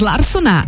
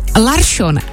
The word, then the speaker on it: Larsson: Z Larsona je najednou Larsson a z Persona Persson.